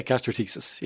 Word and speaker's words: gastroschisis: And you were born, excuse me if I'm saying this right, you were born with gastroschisis? – Yeah, gastroschisis.